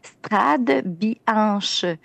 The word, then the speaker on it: Strade Bianche: Frade Bianche, parce que le CH se prononce comme un K, mais bref, si on le lit en français, c'est Strade Bianche.